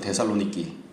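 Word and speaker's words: 데살로니키 (Θεσσαλονίκη): 그들 발음으로는 데살로니키, 데살로니키인데 거기에 갔더니만 거기 유대인 회당이 있었다 이렇게 되어 있습니다.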